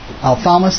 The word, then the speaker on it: ophthalmos: I'm not sure I pronounce it. Ophthalmos. Ophthalmos, thank you.